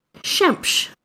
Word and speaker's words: Xemx: There's Shemsh, which means the sun. Which Shemsh? I think is a really... Shemsh. It's spelled X-E-M-X.